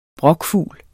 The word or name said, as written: brokfugl